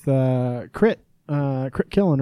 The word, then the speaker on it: Crit Killen: All right, we are here with Crit Killen, right? – Right, Crit Killen.